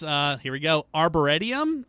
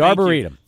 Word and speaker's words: Arboretum: It was at the Dallas, Arboretum. – Arboretum, pal. – There we go. – It's the Arboretum.